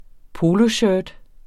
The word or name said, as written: poloshirt